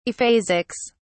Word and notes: aphasics: British English

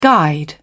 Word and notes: guide: British English

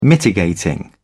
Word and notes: mitigating: British English

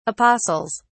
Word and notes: apostles: British English

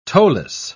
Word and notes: toeless: British English

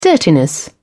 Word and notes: dirtiness: British English

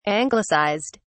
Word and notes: anglicized: British English

